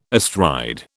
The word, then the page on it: astride: British English